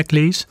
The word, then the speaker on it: glaze: Hvad er glaze? Udtaler jeg det rigtigt? – Ja, udtalelsen er spot on.